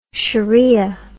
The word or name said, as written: sheria